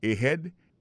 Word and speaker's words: ahead: Ahead. Ahead, là, c'est A-H-E-A-D. Ahead. Ahead. Go ahead. Là encore, on a vu que l'accent tonique là tombé sous deuxième syllabe.